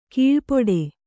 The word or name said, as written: ಕೀಳ್ಪಡು